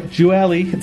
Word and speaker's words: Gioeli: Yeah, no, actually, you did a great pronunciation. So half my family says Gioeli, and the other half says Gioeli. So there's, you can't go wrong.